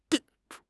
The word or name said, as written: de5